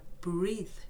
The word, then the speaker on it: breathe: L になっちゃって R breathe breathe 間違えました。